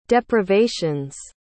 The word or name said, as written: deprivation's